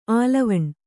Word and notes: ಆಲವಣ್: kannada; ālavaṇ

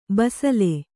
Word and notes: ಬಸಲೆ: basale

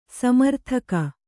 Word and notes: ಸ್ಮರೋದಕ: smarōdaka

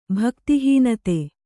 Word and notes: ಭಕ್ತಿಹೀನತೆ: Kannada

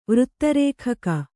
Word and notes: ವೃತ್ತರೇಖಕ: vřtta rēkhaka

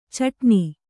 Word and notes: ಛಟ್ಟನೆ: chaṭṭane

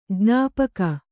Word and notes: ಜ್ಞಾಪಕ: jñāpaka

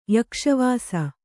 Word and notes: ಯಕ್ಷವಾಸ: Kannada

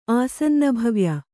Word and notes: ಆಸನ್ನಭವ್ಯೆ: āsannabhavye

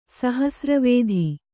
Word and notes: ಸಹಸ್ರವೇಧಿ: sahasra vēdhi

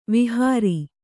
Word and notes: ವಿಹಾರಿ: vihāri

